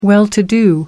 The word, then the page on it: well to do: American English